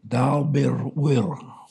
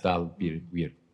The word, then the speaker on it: Dalbirwir: Dalbirwir. – The short I. – Yes, like short I. – Yeah, bir, like the E. Like the sound heard in wit. – Yes. – Dalbirwir.